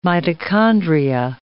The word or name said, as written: mitochondria